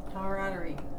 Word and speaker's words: camaraderie: Camaraderie. – Yeah, yep. Camaraderie. – Wrong. – Again. – The noun has a silent A that trips people up. It is pronounced camaraderie.